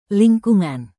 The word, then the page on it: lingkungan: Indonesian